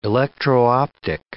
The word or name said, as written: electro-optic